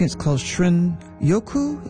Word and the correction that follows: Shinrin-yoku: Is that how you pronounce it? The Japanese term for wood air bathing. – Shinrin-yoku.